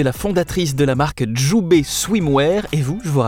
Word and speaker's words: Jubai: Alors Djoubé, ça s'écrit J-U-B-A-I. Swimwear, S-W-I-M-W-E-A-R.